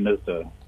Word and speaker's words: Hnizdo: It's a bit unpronounceable. You ignore the H. It's Jan Hnizdo.